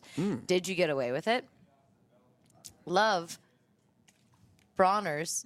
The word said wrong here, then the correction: Buhner: No, Buhner. – Close.